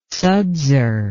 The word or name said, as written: sudser